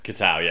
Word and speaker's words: Kitao: Kitao, yeah.